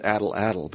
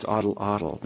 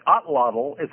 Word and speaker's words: atlatl: So you maybe you could explain a little bit I always pronounced it addle-addle, but it's oddle-oddle. Maybe you could explain what that is for those who don't know. – Okay, so it's a spear thrower. In fact, otlottl, so it's pronounced, even though it's A-T-L-A-T-L, it's pronounced as if it were A-T-L-A-T-L.